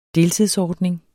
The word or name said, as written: deltidsordning